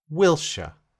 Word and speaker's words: Wilshere: Wilshere.